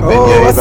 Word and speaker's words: beignets: Talked about that last time, but beignets are delicious. – Oh, I see you got it right this time. – I did. I mispronounced that word all the time. Because it looks like baguette.